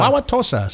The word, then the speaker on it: Wauwatosa: Wauwatosa. See, he said it correctly, too.